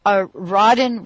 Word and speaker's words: Rodden: Is it considered a Rodden rating? Or am I pronouncing that right? R-O-D-D-E-N.